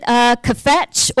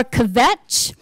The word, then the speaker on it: kvetch: And we pronounce it kvetch or kvetch.